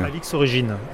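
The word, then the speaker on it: Alix Origines: Alors, je ne sais pas comment vous le prononcez, à la latine Origines, Origines, ou simplement Alix Origine. – Alix Origine, Alix Origine, oui, oui.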